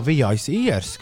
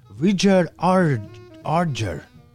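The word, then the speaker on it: Vijay Iyer: Un izrādās, viņš nav jāizrunā kā vijais īrs, kā es domāju, bet Vidžers Arģer. Arģer. Vidžers Arģer.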